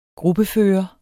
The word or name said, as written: gruppefører